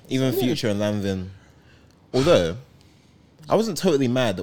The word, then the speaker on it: Lanvin: Hey, there's a Pusha T song about this, and it's pronounced Lanvin.